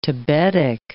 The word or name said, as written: tabetic